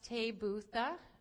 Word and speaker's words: taybutha: It is pronounced tabutha, and T-A-Y-B-U-T-H-A. It comes from a root verb tav, T-A-V, which means to be good.